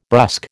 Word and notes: brusque: American English

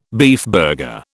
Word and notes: beefburger: British English

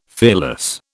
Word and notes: fearless: British English

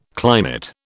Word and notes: climate: British English

